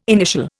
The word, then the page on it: initial: Indian